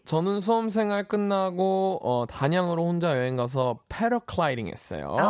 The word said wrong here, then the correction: 패러글라이딩: 아, 이게 영어식 발음이 패러클라이딩인가요? 패러글라이딩이 아니라? – 패러글라이딩입니다. 죄송합니다. 아, 제가 아, 클날 사람이네.